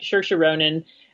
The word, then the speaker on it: Saoirse Ronan: Now, I think I'm getting that right. Saoirse Ronan is how it's pronounced.